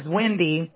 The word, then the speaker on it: Windii: But my first name is Wendy, but my mom was a little weird. So it's spelled W-I-N-D-I-I.